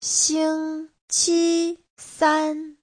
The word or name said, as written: xing1qi1san1